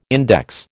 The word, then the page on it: index: American English